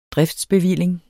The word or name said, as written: driftsbevilling